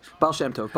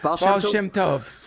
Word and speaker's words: Baal Shem Tov: Baal Shem Tov? – Baal Shem Tov. – Baal Shem Tov.